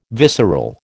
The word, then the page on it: visceral: American English